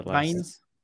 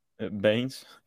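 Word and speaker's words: Bains: Yeah, and Fonon-les... I can't pronounce that. – Baines. – Baines.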